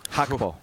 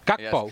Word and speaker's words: Gakpo: Man, I've been pronouncing that wrong. – Gakpo. – Gakpo. – Yes.